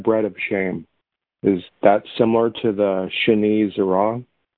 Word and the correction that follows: Shinui Tzura: Is it similar to what? – Is it Shinui Zerah? – Spell it for me. – Shinui Tzura. – Shinui Tzura? – Yes, thank you.